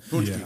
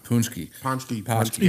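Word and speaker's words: pączki: Poonski. – Yeah. – Poonski. – Even though there's no N when there's nothing.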